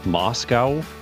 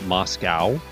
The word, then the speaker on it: Moscow: what is properly pronounced Moscow, not Moscow, Moscow, Idaho.